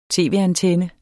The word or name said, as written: tv-antenne